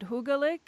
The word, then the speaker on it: hyggelig: But actually, you're going to have to pronounce it hyggelig.